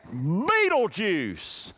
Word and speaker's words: Betelgeuse: Betelgeuse.